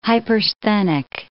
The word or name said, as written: hypersthenic